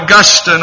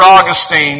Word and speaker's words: Augustine: He was responded to by the great church father, Augustine or Augustine, depending on how you want to call it, to pronounce it.